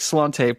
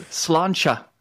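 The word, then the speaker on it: sláinte: And, oh crap, here's the word I was supposed to remember how it goes, which is, crap, it looks like slante, but it's not that. It is pronounced. It is. Oh, it's slancha. Slancha.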